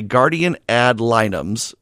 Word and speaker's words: guardian ad litem: But they wanted to know why guardian ad litem.